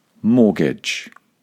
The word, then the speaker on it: mortgage: First of all, the T is completely silent. Imagine it's not there. And secondly, the last syllable is very short. Gudge. So let's put those two things together. Mortgage.